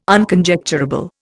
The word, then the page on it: Unconjecturable: Indian